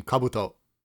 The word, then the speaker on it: Kabuto: That's how you say its name, Kabuto.